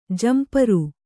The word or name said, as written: ಝಂಪರು